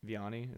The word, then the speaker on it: Vianney: Vianney, is that how you pronounce it? – Yeah.